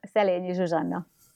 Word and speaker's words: Szelényi Zsuzsanna: Zsuzsa, Szelényi Zsuzsanna.